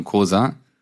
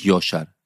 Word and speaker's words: Coşar: Nein, nicht Coşar. Joe. Wie Joker. Coşar. Mhm. Wie Joker und ein Schar Menschen. Coşar.